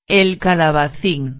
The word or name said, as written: el calabacin